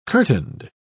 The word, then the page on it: curtained: British English